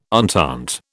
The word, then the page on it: entente: British English